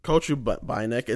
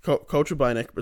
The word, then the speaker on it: Kochubinek: So Nikita Kochubinek. It's they like coach, not Koch. They, you know, they pronounce like the deeper O. So Nikita Kochubinek is pretty much how you say that.